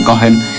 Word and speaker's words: Cohen: Esto es Joy Baron en batería y Greg Cohen, porque me explicaron que la H se pronuncia así, Cohen, en contrabajo.